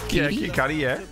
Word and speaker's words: Kid Cudi: She's worked for a bunch of celebrities, clients including Rihanna, I never know how to pronounce his name, Kid Cudi. – Kid Cudi.